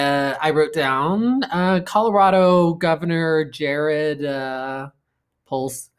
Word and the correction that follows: Polis: How do you say it? Polis? Polis, yeah.